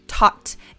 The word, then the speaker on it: taught: Taught. Y la GH pues también es silenciosa.